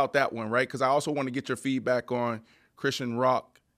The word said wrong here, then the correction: Krishan Rock: Did I say her name right? – Krishan Rock. You want to make sure I get the young lady's name right. Krishan Rock. That was a big one.